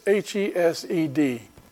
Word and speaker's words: Hesed: It's the Hebrew word, if you, it's H E S E D, Hesed, uh, uh, that it's pronounced.